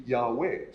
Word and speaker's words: Yahweh: And when he says ye will be, that's really Yahweh. So Yahweh is probably the right pronunciation there.